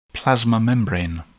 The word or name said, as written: plasma membrane